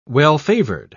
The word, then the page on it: well-favored: British English